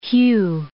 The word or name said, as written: hew